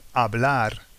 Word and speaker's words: hablar: We don't pronounce the H. It's there, but like if it's not there. Like when we say hola. – Hola. Mm. And also the verb hablar, for example. – To speak, yeah. – Yeah. – So if you think about English when you say our, you don't say hour. – That's right. – So it's that kind of H. It's a silent H.